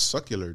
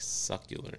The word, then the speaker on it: secular: I was thinking SUCLAR. – That's secular, dude. Secular. Mm. It's secular. – Well, she sounded like succular.